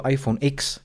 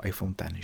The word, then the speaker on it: iPhone X: kdy si řekl iPhone X, tak najednou se na tebe slítli všichni ty zastánci, co jako tomu říkají iPhone 10, protože vlastně Apple to vyslovuje jako iPhone 10, že jo.